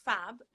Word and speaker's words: Fab: So it's Fab, F-A-B, G-I-O-V-A-N-E-T-T-I, which George spells beautifully, by the way.